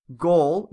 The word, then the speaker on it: Gal: It's pronounced Gaul, G-O-L-L, or is that Gaul? Gaul? – Oh, this isn't helping at all. – Gadot, G-O-D-O-T-E. – Oh. – Because the A's are pronounced as in short O's. So it's like Gaul, Gadot.